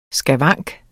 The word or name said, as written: skavank